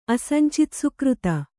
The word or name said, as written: ಅಸಂಚಿತಸುಕೃತ